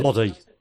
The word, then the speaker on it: Lodi: Lodi.